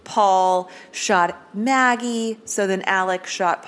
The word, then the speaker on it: Alex: So the theories are either boat crash victims, Stephen Smith, somebody associated with Stephen Smith, or Alex. – Yes, or the dad. – And how old is Alex? – Alec, it's Alec. Alec. I mean, it's spelled Alex, but it's pronounced Alec.